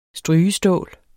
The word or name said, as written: strygestål